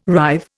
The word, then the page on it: rive: Indian